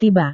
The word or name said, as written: tiba